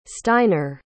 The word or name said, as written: steiner